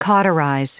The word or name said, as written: cauterize